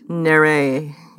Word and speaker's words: Neyret: Neyret.